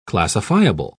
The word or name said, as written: classifiable